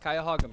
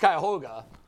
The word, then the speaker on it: Cuyahoga: It's pronounced Cuyahoga McNuggets. – I think it's Cuyahoga. But you say Cuyahoga. – I think it's a Hoga. I say Hoga.